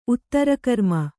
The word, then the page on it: ಉತ್ತರಕರ್ಮ: Kannada